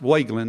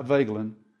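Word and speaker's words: Wegelin: You see, Wageland Bank, and it is pronounced Wageland, not Vagelin.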